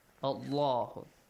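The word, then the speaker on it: الله: The letter lam in the word Allah is pronounced heavily. Allahu.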